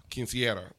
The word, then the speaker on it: Quinceañera: So I did one for Quinceañera.